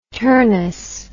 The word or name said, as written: turnus